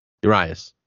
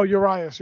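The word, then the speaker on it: Urías: Urias? Is that how you say it? Urias. – Oh, Urias, yeah.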